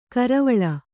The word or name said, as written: ಕರವಾಲ